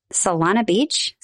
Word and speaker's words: Solana Beach: Then we're going to be near San Diego in Solana Beach. Is that how you say it? Yep.